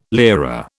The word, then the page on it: lira: British English